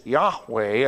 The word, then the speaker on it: Yahweh: The giver of the 10 words, his name is found, which is Yahweh, that's the way we pronounce it, or Jehovah, the self-existent or the eternal one.